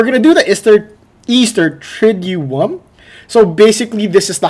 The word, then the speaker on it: Triduum: Today we're going to talk about the Holy Week, specifically on the Easter Triduum. – Triduum? – Triduum.